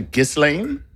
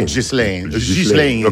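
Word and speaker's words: Ghislain: G-H-I-S-L-A-I-N. I say Gislane. – It's probably Gislane.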